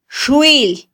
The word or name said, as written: xwil